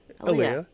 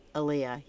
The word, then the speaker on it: Aaliyah: Why can't I say her name? – Aaliyah. – My niece's name is Aaliyah, so I'm like, wait, did I say it right? Aaliyah, yeah.